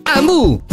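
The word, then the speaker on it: Hamu: Amu si scrive con l'H.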